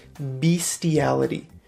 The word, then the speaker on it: bestiality: Okay, but a lot of people say bestiality.